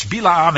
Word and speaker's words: Balaam: Balaam in the English is how people pronounce it, but it's Bilaam in the Hebrew.